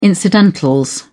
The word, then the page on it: incidentals: British English